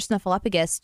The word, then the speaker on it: Snuffleupagus: 30 years ago today, no, this was actually not today, this was like almost a month ago, Mr. Snuffleupagus shocked Sesame Street. This was a really interesting article on a website called Mental Floss. – Did you say Snuffleupagus? – Snuffleupagus.